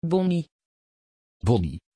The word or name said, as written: bonny